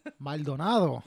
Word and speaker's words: Maldonado: Listen, my last name is phonetically, it's spelled and it's pronounced phonetically. – Yeah. – But people, when I get on the phone, Mr. Madonna. – Madonna. – I'm like, dog, read the name. Maldonado. You could even say it like an American.